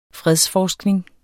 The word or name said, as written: fredsforskning